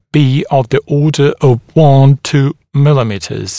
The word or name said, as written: be of the order of 1-2 mm